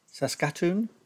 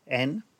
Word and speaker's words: Saskatoon: called Saskatoon, Saskatoon, and on the 2nd of June 1917, Hopkins left Halifax in Canada, and they disembarked in Liverpool a week later.